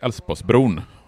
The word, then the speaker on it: Älvsborgsbron: Och den här lille pojken säger Älvsborgsbron och inser, och då inser Torsten Henriksson att det göteborgarna kommer göra, det är att de hoppar över de här besvärliga konsonanterna i uttalet av Älvsborgsbron. Så det blir Älvsborgsbron som man beslutar att den här bron ska heta 1961.